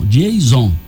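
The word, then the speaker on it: Dieison: Deison Lima, como é que ela pronuncia? Jason Lima, novato.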